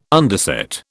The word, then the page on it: underset: British English